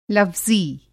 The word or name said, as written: लफ़्ज़ी